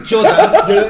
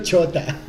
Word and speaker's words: Chota: Y el segundo día era más patético que el primero, que era Choda, que es otro enfermo. Soda, o como se pronuncia la weá. – Chota. Yo lo pronuncio Chota.